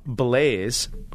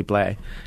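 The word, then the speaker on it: Blais: Now, you may not know, Blaise, which is its way we pronounce it, but it's really Blay, is French-Canadian.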